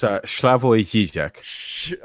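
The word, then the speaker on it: Slavoj Žižek: It's Slavoj Zizek.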